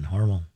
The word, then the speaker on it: Hormel: I ran into some my guy who lived down there and said that's how they say it in Austin, Hormel.